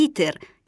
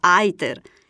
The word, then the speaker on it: iter: Sono invece da evitare le pronunce anglicizzate di iter, che diventa iter, junior, che diventa junior, o plus, pronunciato plus.